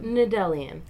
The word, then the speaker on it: Nedelian: Nedelian. – It's pretty easy. – Yeah. Well, I mean, it's like relatively straightforward, but it's like... – It sounds exactly like it's spelled.